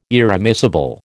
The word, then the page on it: irremissible: American English